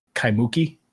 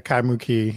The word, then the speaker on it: Kaimuki: Yeah, Kaimuki is how you pronounce it. – Okay, so the emphasis is over the key at the end, so it's Kaimuki? – Yes.